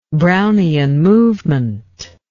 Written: brownian movement

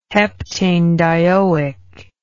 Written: heptanedioic acid

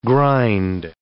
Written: grind

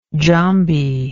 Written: Djambi

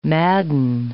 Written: madden

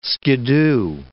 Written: skiddoo

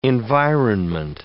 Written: environment

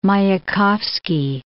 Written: Mayakovski

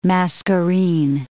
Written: Mascarene Islands

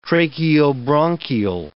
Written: tracheobronchial

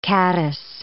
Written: caddice

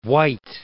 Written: Wight, Isle of

